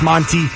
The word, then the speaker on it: Mwznty: Again, that's spelled M-W-Z-N-T-Y, like Muzy. That's Monty.